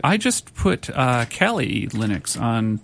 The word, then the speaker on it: Kali: Yeah, if you're interested in security, Kali is the one you want. K-A-L-I.